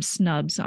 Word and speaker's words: Snubs: And I'm also very active on Instagram and Twitter, and I'm Snubs on both of those, S-N-U-B-S.